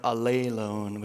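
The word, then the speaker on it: alelone (ἀλλήλων): It's probably better pronounced like alelone, which sounds really cool and maybe Hawaiian a little bit.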